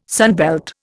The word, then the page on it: sunbelt: Indian